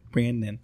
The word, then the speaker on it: Branan: B-R-A-N-A-N. Brannan.